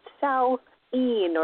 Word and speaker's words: Samhain: And you don't know when you're reading this word that's spelled Samhain. That looks like Samhain. – Mm-hmm. – And until you actually talk to someone, and, you know, some books would say pronounced Sow-een or Sow-in or whatever.